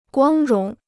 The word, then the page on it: 光荣: guāng róng